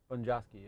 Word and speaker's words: Bonjasky: You had Krokop versus, I don't know how you pronounce the last name, Bonjasky. – Bonjasky, yeah.